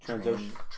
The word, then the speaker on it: Trandoshan: Because I can't imagine there's many Transocean. How do you say it again? – Trandoshan. Perfect.